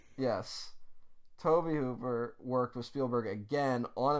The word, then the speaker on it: Tobe Hooper: I failed to do the one thing I promised myself to do, because we got a lot of comments saying we pronounced Toby Hooper's name wrong. – Yes, we did. – And I was insistent to you that it was Toby Hooper. And you were like, no, it's Tobe Hooper.